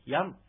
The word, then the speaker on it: ヤン: で、はい、この栗ですが、アイヌ語でヤンと言います。ヤン。ではちょっと 3 人でそれぞれ発音してみます。ヤン。 – ヤン。 – ヤン。 – はい。カタカナでヤームとなってますが、ムというのはちっちゃい、ムですので、口を閉じるだけの発音なので、ヤームとこう、はっきり言うんでなく、ヤンという発音になります。